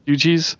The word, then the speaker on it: Fugees: And it was interesting to me to see something like the Fugees. Is that how you say it? – Fugees.